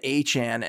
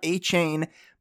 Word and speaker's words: Achane: It's De'Von Achane. And according to Adam Schefter, you might think I pronounced that wrong, but that is how he wants his name produced. It's not Achan, but Achan.